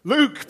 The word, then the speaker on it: look: How do they say it over the Pennines in Lancashire? Luke, perfect.